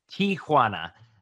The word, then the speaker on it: Tijuana: It's not T I A. It's actually just T I. So it's Tijuana is how you yeah.